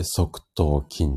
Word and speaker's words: 側頭筋: で、えっ、ー、と、次が、側頭筋っていうところです。これはね、結構わかりやすいかもしれないです。側頭筋は字がね、外側の側っていう字ですね。側っていう字に、頭に筋肉。これで側頭筋って読みます。